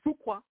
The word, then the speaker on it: Fuqua: And like I said, Fuqua is a good director. I said his name wrong. Fuqua is a great director.